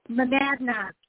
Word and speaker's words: Monadnock: Monadnock. That's how you pronounce that.